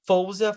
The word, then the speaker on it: Faouzia: Fouzia, Fouzia se escreve, se escreve F-A-O-U-Z-I-A.